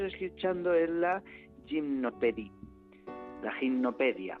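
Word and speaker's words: Gymnopédie: Gimnopedia con Y escrito. Sí. – Y así el que quiera, gimnopedia la primera, ¿no? Es como como la Wikipedia, pero de un gimnasio, ¿no?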